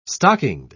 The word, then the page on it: stockinged: British English